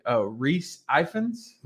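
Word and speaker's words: Rhys Ifans: How'd you say his name? – What did I say? Reese Ifans. – Man, that's good. You probably did better than I was going to do, man.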